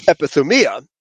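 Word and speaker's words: epithumia (ἐπιθυμία): Or actually it says epithumia is to pronounce the accent on the second last syllable, epithumia.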